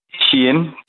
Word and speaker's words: Shein: Jeg skal lige høre dig udtale det igen, så jeg er sikker på, at jeg udtaler det rigtigt. – Shein, det øh, Shein.